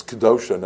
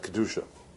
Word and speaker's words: Kedosha: And it's Kedosha, not Kedusha. So while both are right, but many of the Meforshim of the Siddur will tell us that the correct pronunciation is Ubni'ima Kedosha.